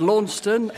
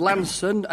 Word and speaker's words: Launceston: I think I heard Launceston and Lanson, and then I sometimes hear Launceston but without the T being pronounced.